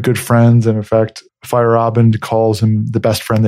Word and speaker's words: Feyerabend: I said Feyerabend? – It's Feyerabend. – Feyerabend. Sorry about that. – Feyerabend. Yeah, Feyerabend. – Yeah. You got to remember that A in the middle of it.